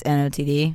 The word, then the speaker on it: NOTD: I don't know if you say this N-O-T-D or if you say it like not. – It's N-O-T-D. – Yeah, N-O-T-D.